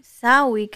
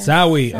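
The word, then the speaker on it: Samhain: Sawi, creo que se pronuncia. – Sawi, ok.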